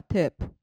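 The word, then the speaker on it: tip: まあ、アメリカでは、ウェイターやウェイトレスはですね、金額の 15% か 20% と言われるチップ、まあ、英語ではテープという発音ですけれども、テープという発音ですけれども、まあ、それを当てにしてサービスしてるのでですね、レストランから受け取る時給自体はですね、本当に微々たるもんなんですね。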